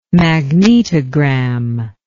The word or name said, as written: magnetogram